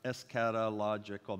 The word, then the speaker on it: eschatological: That's eschatological. That's six syllables.